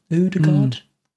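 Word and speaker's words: Ødegaard: I mean, they just bought Uda, Udegaard, Udegaard, Udegaard.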